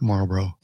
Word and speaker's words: Marlboro: If it's here in America, we pronounce it like the red hard pack, Marlboro.